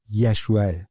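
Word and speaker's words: یشوع (ישוע): عیسی مسیح، تلفظ درست نام عیسی در عبری یشوع، همون یشو، همون نامی که در عهد عتیق برای یوشع به کار رفته.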